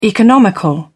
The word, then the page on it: economical: British English